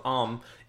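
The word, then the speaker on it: Amn: Okay, just forget the N is there. – Okay. So the release date for Baldur's Gate 2, Shadows of Om, is September 2000.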